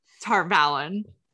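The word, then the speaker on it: Tar Valon: We can call it the right way. We can pronounce it the correct way. – Tarvalon.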